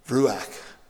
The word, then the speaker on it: ruach: Ruach is the pronunciation of that word.